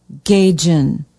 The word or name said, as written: Gagen